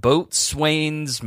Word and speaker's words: boatswain: at the rank of boatswain's mate third class. Am I saying that right, Dev? Boatswain. – Yeah, but that's like I feel like that's a made up title.